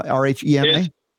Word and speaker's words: Rhema: Uh, and what we're saying here, by the way, uh, if you want to learn about Craig, he has uh, got Re- Rima. Is it pronounced Rima? R H E M A. It's R H. It's Rhema. It's a Greek word um, meaning the spoken word.